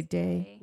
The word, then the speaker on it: Deigh: Is it Day? Is that how you pronounce it? – I believe so. I'd have to find it somewhere in my book. – D-E-I-G-H. – Oh, yeah. I would say Day.